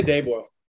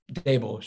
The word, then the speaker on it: Daboll: I say Daboll. – Daboll, sure.